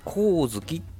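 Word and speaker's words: 上月: まあ、あの、これはですね、ひまわりで有名なですね、コウズキまあ、上に月と書いてですね、えコウズキというところのね、パーキングエリアの上りでですね、えー、見つけました。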